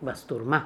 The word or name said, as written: bastourma